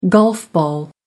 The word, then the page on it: golf ball: American English